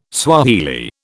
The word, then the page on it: Swahili: British English